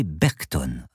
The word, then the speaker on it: Berchton: Paloma toussa discrètement et lança « Professeur Berchton ?» Elle prit bien garde de prononcer « Berchton » à l'anglaise, comme on prononce « Jackson » ou « Washington ».